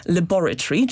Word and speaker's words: laboratory: I jednym z najciekawszych przykładów jest tutaj słowo laboratory, czyli laboratorium, które w brytyjskim angielskim wymawiane jest akcentem na drugą sylabę. I to jest właśnie wynik tej rekomendacji. Dlaczego nie akcent na pierwszą sylabę? Dlaczego nie wymawiamy tego słowa jako laboratory? Albo tak jak Amerykanie też z akcentem na pierwszą sylabę laboratory. Ano dlatego, że uznano, że ta wymowa laboratory z akcentem na pierwszą sylabę za bardzo przypominałaby wymowę słowa lavatory, które oznacza toaletę.